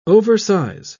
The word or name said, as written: oversize